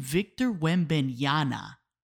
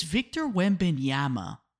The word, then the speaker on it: Wembanyama: calling him Victor Wembanyama with an N, N for Nancy, in case you can't hear the pronunciation well over the headphones. It's Victor Wembanyama, guys, with an M, M for mama, okay?